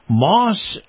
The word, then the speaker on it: Maus: This portion of the program brought to you by Moss Nissan. Moss is spelled M-A-U-S.